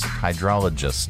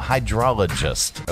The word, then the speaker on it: hydrologist: Yeah, uh, this is uh, eco hydrolist hydro hydrolist. Hydrolist, hydrologist. Oh, hydrologist. Okay, an eco hydrologist.